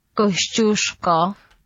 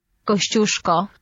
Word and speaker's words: Kościuszko: Kościuszko. – Kościuszko.